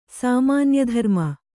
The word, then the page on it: ಸಾಮಾನ್ಯಧರ್ಮ: sāmānya dharma